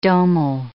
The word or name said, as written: domal